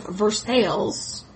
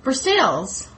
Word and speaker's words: Versailles: And then another sale of goods and more people and another move, this time to a farm outside of Versailles. – Versailles, Kentucky. – Which bears no resemblance to Versailles, even though it's spelled the same.